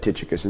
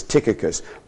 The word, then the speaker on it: Tychicus: You noticed I pronounced the name Tychicus two different ways. And all my life, I've said Tychicus. And then I looked this week in the dictionary, and it's Tychicus.